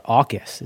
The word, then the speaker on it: AUKUS: called AUKUS, or AUKUS is how they pronounce it.